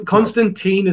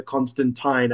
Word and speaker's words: Constantine: Constantine is the American pronunciation because that's Yeah. – how Americans read it. But it is named after Constantine, as in the Tyne Bridge.